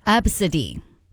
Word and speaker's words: Abcde: The name is spelled A-B-C-D-E. Yes, like the first five letters of the alphabet. But no, it is not pronounced A-B-C-D-E. It's pronounced Absidy.